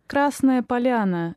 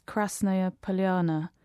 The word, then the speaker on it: Krasnaya Polyana: Okay, it's Krasnaya Polyana in Russian. So we anglicize it as Krasnaya Polyana. So the stress in the first word is actually on the first syllable, but people often see Russian words that end with A-Y-A and assume that the stress is on the penultimate syllable, but it's not always the case.